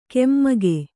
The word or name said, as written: ಕೆಮ್ಮಗೆ